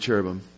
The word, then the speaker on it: cherubim: And we say cherub, we're talking about one, but we say cherubim, in the Hebrew, you pronounce like the im, I am. It's a plural. We have the S at the end of our words, they have I am.